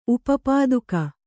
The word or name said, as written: ಉಪಪಾದುಕ